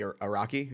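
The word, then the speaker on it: Arakko: Am I pronouncing that correctly? I've only read it. – Yes. People have different. That's generally the way I say the Araki, I know people who say the Araki. It's a fictional place. It's difficult to kind of say what the right pronunciation.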